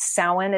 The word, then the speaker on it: Samhain: And when you hear me say Samhain, it's actually spelled Samhain. So a lot of people, like, if you've ever seen the word Samhain, it's pronounced Samhain. It's a Gaelic word.